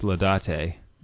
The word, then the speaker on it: Laudate: Okay, well, if you're saying it Ladate, let me just, out of Christian charity, correct you because you're saying it wrong. It's Ladate. It's spelled Laudate. So I guess I see why you would say Ladate, but it's a Latin word, and so it's pronounced Ladate.